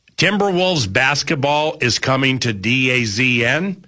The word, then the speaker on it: DAZN: I guess, according to the press release, you pronounce it DAZN. – Oh, DAZN.